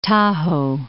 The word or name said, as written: Tajo